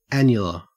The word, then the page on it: annular: Australian English